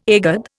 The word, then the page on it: egad: Indian